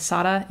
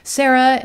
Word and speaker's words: Sarah: For the record, my name is Sara. It's Sarah, S-A-R-A-H. The Spanish pronunciation, though, that's what my family calls me. Yes, I know that Sara in Spanish does not have an H.